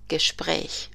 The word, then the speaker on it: Gespräch: Acuérdate que los dos puntos La diéresis encima de la A se pronuncia como una E. Das Gespräch.